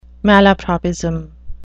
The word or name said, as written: malapropism